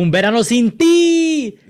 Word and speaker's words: Un Verano Sin Ti: Oh, Un Verano Sin Ti.